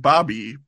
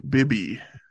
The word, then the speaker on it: Bibby: Okay, not Mike Bobby, but Mike Bibby.